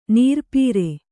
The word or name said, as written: ನೀರ್ಪೀರೆ